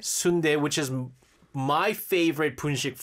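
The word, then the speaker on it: sundae (순대): So if it's Romanized, if the menu has the English, they think they're being helpful by putting, you know, the English underneath, it will be spelled exactly like sundae, yeah. – But it's pronounced sundae and it's pig intestines, essentially.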